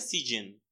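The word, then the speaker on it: Siccin: Creo que se pronuncia Sijin. Porque las C se pronuncian como J.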